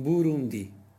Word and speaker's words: Burundi: a tónica em Burundi e afins é flutuante, já em rum, na sílaba rum, já na última sílaba, di.